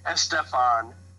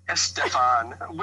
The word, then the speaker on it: Estefan: Estefan. Estefan, which is the proper pronunciation.